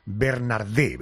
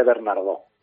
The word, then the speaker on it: Bernardeau: El apellido del creador de la serie Cuéntame, de Miguel Ángel, es Bernardé, ¿verdad? – Se escribe Bernardeau y se dice Bernardo.